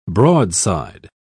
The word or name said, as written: broadside